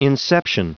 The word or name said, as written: inception